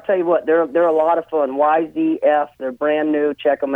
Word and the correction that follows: YCF: YCF. – YC, like an ABC, yep.